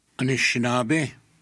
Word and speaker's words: Anishinaabe: Anishinaabe.